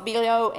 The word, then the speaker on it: Biello: I'm going to butcher his last name, Biello, B-I-E-L-L-O, Biello.